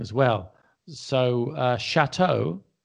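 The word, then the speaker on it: châteaux: I mean, in the French, you'd put an X afterwards, but you still say chateau. The pronunciation wouldn't change. And in English, you might say chateaus, two chateaus.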